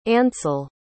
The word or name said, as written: ansel